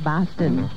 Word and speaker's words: Boston: But they've got the pronunciation of Boston wrong. If you're from out east, it's pronounced Boston.